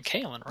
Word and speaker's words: Kalen: It is a long A in Kalen, right? – It is, yes.